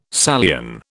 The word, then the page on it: Salian: British English